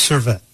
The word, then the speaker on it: Servette: So your first club was Servette? – Yes.